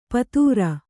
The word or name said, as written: ಪುತ್ರಿ